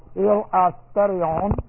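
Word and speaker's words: Il asterion (ἱλαστήριον): and I've kind of hyphenated or broke the word up so you get a little feel of how the word is pronounced. Il asterion.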